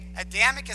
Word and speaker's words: Adamic: No, okay, Adamic is spelled A-D-A-M-I-C. Okay, Adam, you know, Adam and Eve.